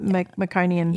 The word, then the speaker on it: meconium: Meconium.